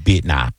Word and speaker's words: Bitnah: And I was like, how do you pronounce the name? And then they said, Bitnah.